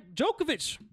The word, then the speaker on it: Djokovic: We got Novak Djokovic. – The tennis player. – Yep. – And you pronounced his name right. – Yeah. Well, I know that because the D is sounding like Django.